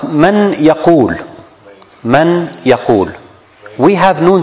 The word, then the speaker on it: من يقول: We should put an X on the noon and put shadda on the ya. So we pronounce it man yaqul.